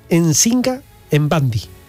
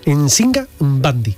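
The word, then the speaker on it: Nzinga Mbandi: Pero fijaros, se llamaba Nsinga Mbandi. así hay que pronunciar las M, ¿eh?, y las N. En Singa Mbandi.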